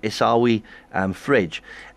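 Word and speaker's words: Esawi Frej: And I hope I pronounce his name right, Esawi Fredge. Why was this visit so significant, and who exactly is Esawi Fredge? – Well, first of all, I have to commend you on your pronunciation.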